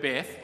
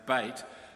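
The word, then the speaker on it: Beit (בית): The Hebrew word Beth, or more properly pronounced Beit, means house.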